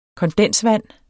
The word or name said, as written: kondensvand